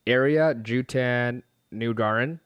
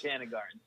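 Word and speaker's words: Ariya Jutanugarn: I've not seen that, but I know, very nice pronunciation. – Was that close? – A couple of pauses in there, but you are exactly right. Yes. It was Jutan Nugaran.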